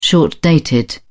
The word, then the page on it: short-dated: British English